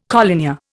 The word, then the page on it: collinear: Indian